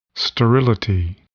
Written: sterility

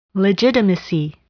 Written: legitimacy

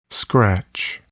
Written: scratch

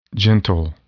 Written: gentle

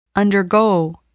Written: undergo

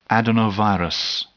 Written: adenovirus

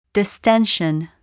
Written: distension

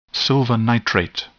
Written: silver nitrate

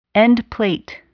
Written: endplate

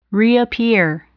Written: reappear

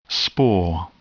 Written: spore

